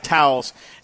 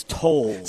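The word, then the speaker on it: Towles: I said it was more like Towels, and Fred Fowler was quick to text me and say, it's Tolles.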